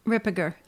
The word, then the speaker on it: Ripperger: I'm sure you're aware of the famous exorcist, Chad Ripperger. Am I saying his name correctly? – Ripperger. Uh-huh. Yes.